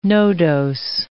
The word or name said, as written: nodose